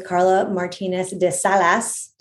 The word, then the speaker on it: Karla Martínez de Salas: We're here with Karla Martinez de Salas.